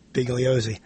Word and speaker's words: Bugliosi: I was thinking about you not because of Bugliosi. Did I say that right? – Yes. – Yeah, Vincent Bugliosi. – Vincent Bugliosi.